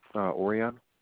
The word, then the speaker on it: Orion: Orion. – Aurea. – Orion, Orion. – Orion. O-R-I.